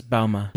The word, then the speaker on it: Balmain: I don't know if I'm even saying that right. Balmain. – No, it's Balmain.